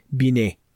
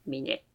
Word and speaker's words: Binet: Then I believe his uh, surname is pronounced Binet. – Binet.